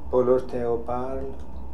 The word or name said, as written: Polorotheoparl